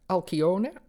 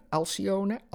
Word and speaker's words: Alcyone: Die ontstaat in de centrale ster, Alcyone, Alcyone, Alcyone, hoe je het mag uitspreken, dat maakt niet uit.